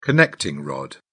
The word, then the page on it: connecting rod: British English